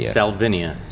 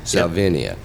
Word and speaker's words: Salvinia: Am I saying that right? Is it Salvinia or Salvinia? – Salvinia. – Salvinia. – Yep.